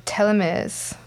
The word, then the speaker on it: telomeres: The other section, about Carol Gredier, the American molecular biologist who won the Nobel Prize in 2009 for discovering how telomeres, is that how you say it? – That's it, yep.